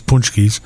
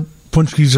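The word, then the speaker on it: pączki: And Jerish has some great Punchkis, and I asked them how to pronounce it. It's Punchkis. – Punchkis or Punchkis? Somewhere in between there, I think.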